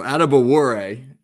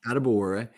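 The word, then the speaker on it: Adebawore: Are we even close with the pronunciation? – So Adebuwore, not bad at all. Adebuwore.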